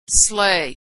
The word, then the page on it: sleigh: American English